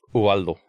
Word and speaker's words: Ubaldo: It's Ubaldo. – B or V? – No, B. – B. – Yeah, so a lot of people like to emphasize the U in it.